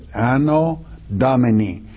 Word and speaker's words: Anno Domini: It's Latin, the term AD. And it is, the Latin, it is two words, Anno Domini, the year of our Lord. That's how you say it, Anno Domini.